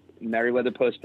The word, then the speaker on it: Meriwether Post: I cannot wait for May 3rd, 4th, and 5th in Columbia, Maryland at the Mary what Mary Weller what how do you say it? Mary Weller Pavilion? – Meriwether Post. – Ma- Post, right. – M- Meriwether Post Pavilion,